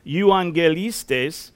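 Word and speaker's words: euangelistes (εὐαγγελιστής): The Greek noun for evangelist is euangelistes, and it is pronounced euangelistes, and it's Strong's number 2099, the very next one, and it means a bringer of good news.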